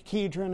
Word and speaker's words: Kidron: That brook Kidron there, it's pronounced Cedron in the Greek, Kidron in the Hebrew.